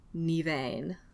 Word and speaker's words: Nemain: I think it's probably pronounced Nivain, but it's spelled like, the spelling I wrote down was N-E-M-A-I-N, but there are also versions where it's spelled weird because Irish is a weird language.